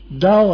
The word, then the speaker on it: Tao: Tao, I think, is the correct Chinese or Japanese way, isn't it? It's pronounced like a D.